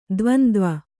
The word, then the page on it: ದ್ವಂದ್ವ: dvandva